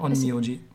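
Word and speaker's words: Onmyōji: Man nannte die Onmyoji. Habe ich das richtig ausgesprochen? – Naja, ja, Onmyoji. Ja.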